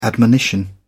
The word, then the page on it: admonition: British English